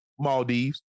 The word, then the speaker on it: Maldives: How you say it? – Maldives.